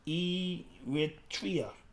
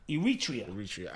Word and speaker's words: Eritrea: Oh, it's pronounced Eritrea. – Eritrea.